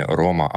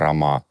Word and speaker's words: рома: И немножко дополню, кстати, будет правильно произноситься не Рома, а Рома с ударением на последний слог.